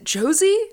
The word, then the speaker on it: Josie: I was wondering why downstairs you were like very confused by when you were like, is it Josie? And I was like, yeah. Exactly how it's spelled.